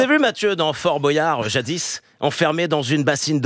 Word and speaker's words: Boyard: Non, ouais, c'est Boyard. Frère, on devrait dire Boyard. – Ah, on dirait Boyard, j'avais raison. Oui. Boyard comme Boyau, voilà.